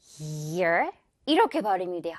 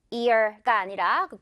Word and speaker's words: year: 이거 year 이렇게 발음이 돼요. 그냥 단순히 ear가 아니라 그 귀가 아니라 이 선풍기 소리 year.